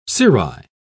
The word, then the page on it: cirri: British English